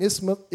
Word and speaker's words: isthmus: And Corinth was a peninsula, like an isthmus. How do you say that word? Isthmus, like Christmas without the isthmus.